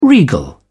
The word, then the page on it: regal: British English